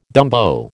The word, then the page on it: dumbo: American English